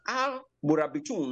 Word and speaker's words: Al Murabitun: And the group that brought Islam were called the Almoravids and so this group, known as Al Murabitun, um, that is to pronounce Amurabit, Amuravit in Arabic. So it becomes Al Murabitun.